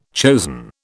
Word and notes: chosen: British English

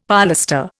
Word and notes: polestar: Indian

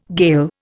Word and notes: Gael: Indian

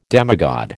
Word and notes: demigod: American English

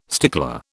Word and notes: stickler: British English

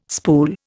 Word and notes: spool: Indian

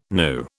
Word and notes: gnu: British English

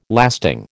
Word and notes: lasting: American English

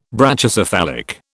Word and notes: Brachycephalic: British English

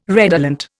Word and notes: redolent: Indian

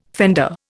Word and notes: fender: Indian

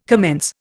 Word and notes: commence: Indian

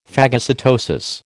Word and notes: phagocytosis: American English

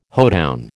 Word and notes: hoedown: American English

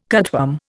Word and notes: cutworm: Indian

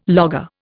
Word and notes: logger: Indian

